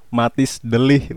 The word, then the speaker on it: Matthijs de Ligt: Iya, pengucapannya tuh yang benar Matthijs de Ligt, Bung.